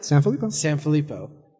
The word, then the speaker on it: Sanphillippo: Sanphillippo.